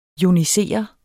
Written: joniser

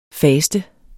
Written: faster

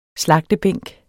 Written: slagtebænk

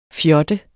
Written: fjotte

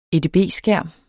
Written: edb-skærm

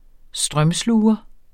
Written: strømsluger

